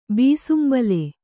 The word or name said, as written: ಬೀಸುಂಬಲೆ